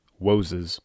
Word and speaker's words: Woses: It's just an odd and pleasing sounding word, Woses. It's like you're mispronouncing it, but you're not.